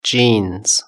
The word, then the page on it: jeans: British English